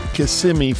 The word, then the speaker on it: Kissimmee: The original plan called for us to pay a visit to Kissimmee, Florida, down in Osceola County. And the mere fact that I have pronounced both of those names properly tells you that I've ridden through there a time or two.